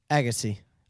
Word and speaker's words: Agassi: Agassi? Is that how you say it? – Agassi.